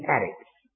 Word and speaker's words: Aritz: And the word earth in the Hebrew language is Eretz, and we spell it E-R-E-T-Z, Eretz. Now the word for oppressor is Aritz, A-R-I-T-Z.